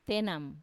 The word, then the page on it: tenam: Wamesa